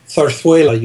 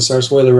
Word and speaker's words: zarzuela: In Castilian Spanish, of course, it's pronounced zarzuela. You lisp the Z's.